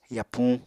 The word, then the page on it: yapuñ: Apatani